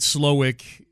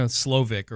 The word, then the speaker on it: Slowik: S-L-O-W-I-K. – Yeah, yeah. You know, you never know. Sometimes those W's are pronounced like V's in some of these names. You know, Slovic or whatever.